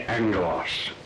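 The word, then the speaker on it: angelos (ἄγγελος): To spell it out, it would be A-G-G-E-L-O-S. And in Greek, where two G's come together, the first one is always pronounced. And if we're translated, literating it into English, we put an N. And this would be angelos, A-N-G-E-L-O-S.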